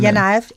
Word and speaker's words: Janajev: Janajev, tror jeg han hedder, du må korrigere udtalen. – Janajev, ja. Janajev.